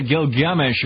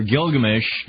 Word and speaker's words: Gilgamesh: from the time that Gilgamesh, or Gilgamesh, depending on how you pronounce it,